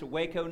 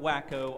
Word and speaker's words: Waco: Pronounced Waco, not Wacko, although those of us who are from Waco sometimes can rightly be referred to as Wacko.